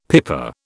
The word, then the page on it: pipa: British English